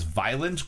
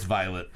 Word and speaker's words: Violet: The girl in the store pronounced it as Violent Crumble. – Violent. – And I was like, whoa! Oh, it's Violet.